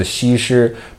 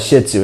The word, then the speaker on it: Shih Tzu: now in chinese it's a shishu but we call it shih tzu it's the japanese pronunciation i like to say that it's the english pronunciation because these dogs poop a lot, all the time.